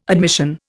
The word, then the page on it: admission: Indian